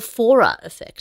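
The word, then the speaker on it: Forer effect: to the Fora effect.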